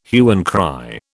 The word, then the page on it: hue and cry: British English